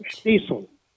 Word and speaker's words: Shtisel: S-H-T-I-S-E-L. Schnitzel. S-H-T-I-S-E-L.